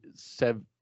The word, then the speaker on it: Svechnikov: Andrei Svechnikov. – Yep, there you go.